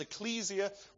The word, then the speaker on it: ecclesia: Actually, the English word church that we have in our Bibles comes from a Greek word that you pronounce ecclesia, which simply means an assembly, a gathering together of people.